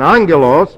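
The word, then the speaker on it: angelos (ἄγγελος): And angelos, by the way, double gammas in the Greek language simply indicate a diphthong, and the diphthong is pronounced NG. We transliterate it A-N-G-E-L.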